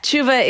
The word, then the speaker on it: Shuva: and I also understand that you have interpreted repentance through something called the Shuva. Am I saying that correctly? – Yeah. Shuva is the Jewish word for repentance, though it has slightly different meanings, but yeah.